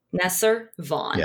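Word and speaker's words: Neser Vahn: Nesser Vaughn.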